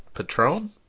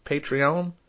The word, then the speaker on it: Patreon: You know what's not lame is our actual uh, our pa- patron Patreon.